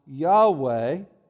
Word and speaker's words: Yahweh: Now, that's actually four Hebrew consonants, which we generally pronounce Yahweh.